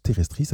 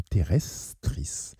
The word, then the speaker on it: Tribulus terrestris: ce qui est valable également pour le Tribulus terrestris. Tribulus, comme ça se prononce, terrestris, avec deux R comme terrestris.